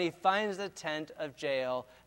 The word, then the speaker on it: Jael: And the word Jael rhymes with nail, and it's easy to remember the story this way, how that Sisera is running from the battle.